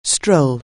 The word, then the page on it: stroll: anglais